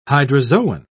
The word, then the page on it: hydrozoan: British English